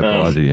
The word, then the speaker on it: Balaji: And I think, you know, Balaji, is that how you say his name, right? – Yeah, Balaji, yeah.